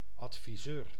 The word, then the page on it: conseiller: France; /kɔ̃.se.je/